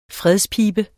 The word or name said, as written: fredspibe